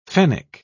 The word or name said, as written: fennec